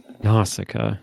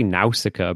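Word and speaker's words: Nausicaä: I say Nausicaa. – Nausicaa. – Like, yeah, nautical. That's where my brain was at, so. – That makes so much sense. I say Nausicaa, but I think you're right.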